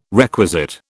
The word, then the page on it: requisite: British English